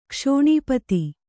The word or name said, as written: ಕ್ಷೋಣೀಪತಿ